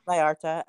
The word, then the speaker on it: Vallarta: And then it says Lance was in Puerto Vallarta. I'm probably saying that wrong. – Vallarta. – Okay.